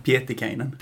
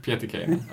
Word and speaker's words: Pietikäinen: Pietikainen. Pietikainen.